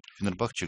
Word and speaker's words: Фенербахче: Первая часть, где ударение делать, Фенербахче или Фенербахче? – Фенербахче, говорят.